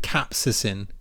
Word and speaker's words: capsaicin: I don't think I'm saying that right, but I'm going to have to Google this now. How to say capsaicin? – Capsaicin. – Yeah. Spicy bits. Right. – Capsaicin. Okay. There we go.